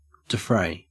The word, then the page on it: defray: Australian English